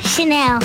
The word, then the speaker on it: Chanel: Chanel. – Oh. So you know how to pronounce it. It's not channel.